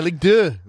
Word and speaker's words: Ligue 2: I don't know how to say it in French, but Ligue 2, Ligue 2, I guess. Ligue 2. Is that right? – Yeah, Ligue 2. That's right.